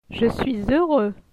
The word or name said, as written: Je suis heureux